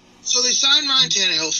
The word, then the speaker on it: Tannehill: He wants to know, what is your expectations for the Titans this year, and why did they sign Tannehill, if I said his name correctly? – Oh, Ryan Tannehill, yes, you did.